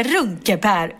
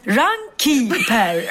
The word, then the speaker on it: Runkeeper: Och då insåg jag att Runkepär uttalas inte Runkepär utan Runkepär. Runkeeper, Runkepär.